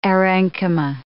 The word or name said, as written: aerenchyma